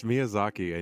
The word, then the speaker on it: Miyazaki: Wait, you guys, it's Miyazaki, and you haven't seen Princess Mononoke? – I have countless times.